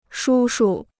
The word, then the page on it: 叔叔: shū shu